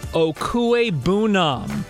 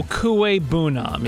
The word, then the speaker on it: Okwuegbunam: It's Albert Okwuegbunam. Okwuegbunam, yes.